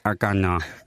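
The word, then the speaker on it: Hagåtña: Hovedstaden hedder Hagatna, tidligere Agatna, udtalt Agatna.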